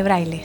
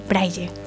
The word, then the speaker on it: braille: Y yo me indigné porque no se dice braille, se dice braille.